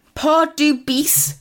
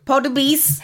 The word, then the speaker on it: Pardubice: It's Pardubice. – Who? – Pardubice. – How do you spell that, please? – P-A-R-D.